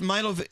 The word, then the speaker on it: Milo: It's Milo.